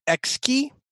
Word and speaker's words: Xiki: Our tip and trick this week is, and I'm not exactly sure how to pronounce this, but XKey, I'm going to pronounce it. It is a command line jockeys utility, XIKI, or XKey as I'm pronouncing it, is a truly amazing, useful open source enhancement for command line shells.